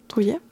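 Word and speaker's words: Druillet: Je prononce bien Drouillet.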